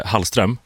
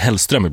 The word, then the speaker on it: Hallström: Jag och vår kära kollega Michelle Hallström, som många blandade ihop och skulle brukar säga Hellström ibland.